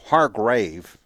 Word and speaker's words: Hargrave: You know, the ability to add a Javon Hargrove, for example, Hargrave, I keep on mispronouncing his name, Hargrave, or not mispronouncing, just mis-saying it.